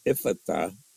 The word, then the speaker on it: Ephphatha: He said, I pronounce it, Ephphatha.